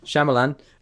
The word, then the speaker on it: Shyamalan: Night Shyamalan, I can't say his name. – Shyamalan,